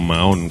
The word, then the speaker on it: Mahon: Mahon, que sería como Mahon, como donde hacen los quesos allá, creo, en Menorca. Pues exactamente igual, E.L. Mahon. Entonces se pronuncia E.L.